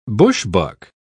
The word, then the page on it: bushbuck: British English